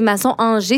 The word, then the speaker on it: Masson-Angers: Si, je ne sais pas si c'est Masson-Angers, si je le prononce bien.